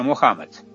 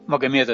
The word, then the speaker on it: Мухаммад: А племя Курейш, оно в Коране прописано многократно, из него вышел пророк Магомед, он же Мухаммад. Магомед – это французское так сказать, произношение.